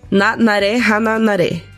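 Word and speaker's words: Nanare Hananare: Que o anime Nanari Hana Nari? É isso? – Nanaré Hananaré. Olha, ele pronuncia muito melhor do que eu.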